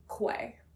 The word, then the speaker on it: Quay: And there's also that brand Key that's, like, very inexpensive from Nordstrom, but, like, looks so chic. That one's a good one. – I haven't heard of Key. – It's spelled, like, Quay. Quay.